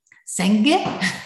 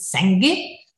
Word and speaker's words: Senge: En toen dacht ik van ja, maar hoe spreek je nou de, naam uit van deze auteur? Ja, nou, die spreek je verschillend uit. In het Duits is het Senge. In het Deens is het Senge. Maar je hebt ook Senge. En, en, en, en uiteindelijk vonden we een site en die zei van ja, het is Pieter Sengi.